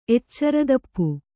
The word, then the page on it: ಎಚ್ಚರತಪ್ಪು: eccaratappau